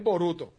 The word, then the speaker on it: Boruto: Burrito, yeah, Burrito. Mira, Spider me confirma, es Boruto. Boruto, gracias. Lo dije mal.